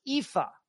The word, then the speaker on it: Aoife: Ayoif. Hello once again from Edmonton. It's always so enjoyable listening to you. Oh, there we go. It's pronounced Aoife. Pronounced Colleen. Aoife, Colleen. Both Irish names meaning beautiful daughter.